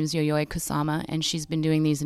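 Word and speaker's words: Yayoi Kusama: Shout outs to Yoyoi Kusama.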